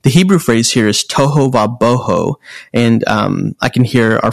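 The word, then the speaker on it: tohu va-bohu: So I'm going to spell it T-O-H-U, toho va-w-a, boho, B-O-H-U.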